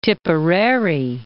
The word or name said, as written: Tipperary